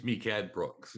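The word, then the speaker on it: Mehcad Brooks: played by I think it's DeCobb. How do I pronounce that? I should have thought of that. – I think it's Mikad Brooks.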